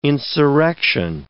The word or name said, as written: insurrection